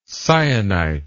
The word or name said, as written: syenite